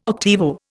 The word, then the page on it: octavo: Indian